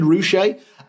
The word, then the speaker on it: Ruscha: The man I dubbed Edward Ruscha or Ruscha or Rushka is actually called, or his name is actually pronounced, Edward Ruscha. I'm awfully sorry about that, Richard Ruscha. As a man, I can certainly empathise with the irritation of having someone mispronouncing your name.